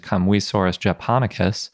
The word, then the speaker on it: Kamuisaurus japonicus: Up next, we heard from Yoshitsugu Kobayashi, and he presented on the dinosaur that he described, which is Kamuisaurus japonicus, and now I can be pretty confident in that pronunciation.